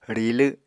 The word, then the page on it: rilw: Apatani